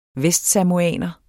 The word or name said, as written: vestsamoaner